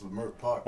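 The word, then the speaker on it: Leimert Park: You can't say it like that. – How do I say it again? – It's Lamert Park. – I'm from Lamert Park. – That's how you gotta say it, Lamert Park, not Lamert.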